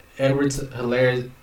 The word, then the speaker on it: Edwards-Helaire: but I think Edwards-Helaire, the H is silent. I just learned that.